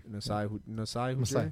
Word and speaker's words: Masai Ujiri: Masai Ujiri. – Masai. So I said it right.